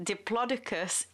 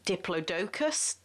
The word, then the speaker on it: Diplodocus: According to the Oxford English Dictionary, all are acceptable, but the BBC pronunci- pronunciate I can't pronounce the word pronunciation unit has decided that the correct way to say it is diplodocus, and as this is a science broadcast, I'm going to be saying diplodocus the correct way.